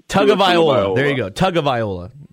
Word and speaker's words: Tagovailoa: And if Tua Tagovailoa, for my, however you say his name. – Tua Tagovailoa. – It's not tongue. It's tag.